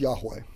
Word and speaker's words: Yahweh: But if you use the actual vowel pointings that were a part of the Tetragrammaton, the Yahweh, it should be pronounced Yahweh. Just a little tidbit there. As Jehovah Witnesses make a big deal about his name as Jehovah and they're not even using the right pronunciation.